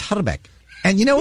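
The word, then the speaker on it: Hrbek: I speak a little bit of Czech, and the actual correct pronunciation of his name is Herbeck. You got to roll the I. Herbeck.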